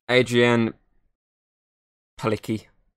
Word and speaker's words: Adrienne Palicki: Andrea Palicki. – Adrienne Palicki. I think that's the closest way you can pronounce this.